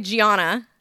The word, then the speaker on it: Gianna: G-I-A-N-N-A. It's not Gina. It's not Joanna. It's not Donna.